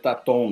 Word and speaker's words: Taton: Pode ser Taton mesmo, acho que é, todo mundo conhece mais como Taton.